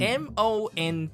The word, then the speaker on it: Mont: M O N T, Mont. Okay, I, I'm pronouncing it Mont. Technically, in French, there's no T, so it's Mon. Mon.